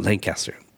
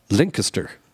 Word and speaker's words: Lancaster: That's not how you pronounce the name of the town. It's not Lancaster. – What do they say? – Lancaster.